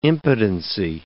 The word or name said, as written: impotency